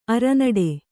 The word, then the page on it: ಅರನಟ್ಟು: aranaṭṭu